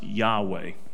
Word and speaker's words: Yahweh: So you see oftentimes when it's spelled out, it's Y-H-W-H. It should be coming up on the screen here soon. And it is pronounced, usually pronounced Yahweh.